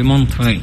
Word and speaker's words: Montreux: Sì, già pensare a un gruppo napoletano al Festival del Jazz, l'International Jazz Festival di Montreux, come si pronuncia? – Montreux. Con la R motion, è una grandissima.